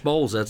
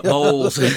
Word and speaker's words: Bowles: Bowl, yeah. Bowles. Yeah, I've got a lot of mispronunciation of my name over the years, but it's just, yeah, B-O-W-L-E-S. It's a little bit weird. A lot of people spell it without the E, but, you know, it happens.